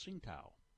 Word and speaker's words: Tsingtao: And if you want to pronounce that in Chinese, it's Tsingtao.